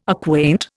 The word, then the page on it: acquaint: Indian